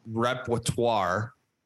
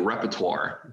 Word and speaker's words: repertoire: How do you say that word? Tell me. You're the teacher. – Repertoire of jerseys. – You totally botched it. You weren't even close. Say it with me. Repertoire.